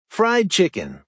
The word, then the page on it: fried chicken: American English